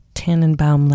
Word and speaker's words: Tannenbaum: Tannenbaum, like the Christmas song, oh, Tannenbaum. It's spelled exactly the same.